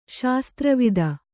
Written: ಶಸ್ತ್ರವಿದ

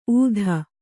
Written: ಊಧ

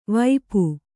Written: ವಾಪಿ

